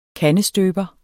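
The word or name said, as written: KANDESTØBERE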